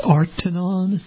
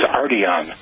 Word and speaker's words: Arteon: This is called the, is it pronounced Artanon? – I got it directly from Volkswagen that it is Arteon, which might not have been my first guess, but it is the Volkswagen Arteon.